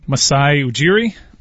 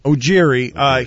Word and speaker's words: Masai Ujiri: Masai Ujiri? – Ujiri.